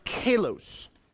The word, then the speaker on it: kalos: And that word, or those words, honorable conduct, it's translated into one word in the Greek language, and it's called or pronounced kalos.